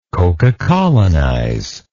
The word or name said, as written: cocacolonization